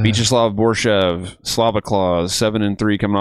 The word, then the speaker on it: Viacheslav: Vyacheslav, that's how you say it. All right. Vyacheslav.